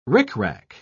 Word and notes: ricrac: British English

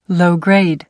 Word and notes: low-grade: American English

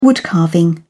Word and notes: woodcarving: British English

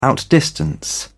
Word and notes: outdistance: British English

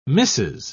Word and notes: missis: British English

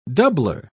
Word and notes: doubler: British English

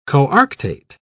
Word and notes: coarctate: British English

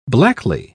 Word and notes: blackly: British English

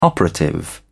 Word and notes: operative: British English

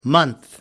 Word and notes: month: American English

